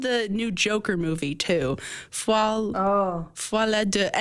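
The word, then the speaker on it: Folie à Deux: Foie la deux? I can't speak French. How do you pronounce that? – That was really good.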